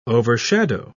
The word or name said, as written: overshadow